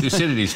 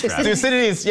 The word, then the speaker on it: Thucydides: I cannot pronounce the name of the Greek philosopher that the Chinese president mentioned. Do you know what? Thucydides. – Thucydides, yes.